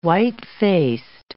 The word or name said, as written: white-faced